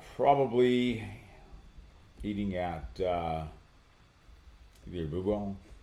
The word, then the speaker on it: Bubo: B-U-B-O. Bubo.